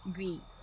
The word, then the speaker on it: Gui: Call me Gui na lang pala, pronounced like T. So feeling ko man tama naman yung pagkakasabi. Gui, T.